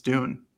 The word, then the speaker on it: Doon: Well, I asked Nate Kruger, our Oregon's SID for the football team, and he confirmed it's Doon. So okay. – Yeah. It like wasn't just Don.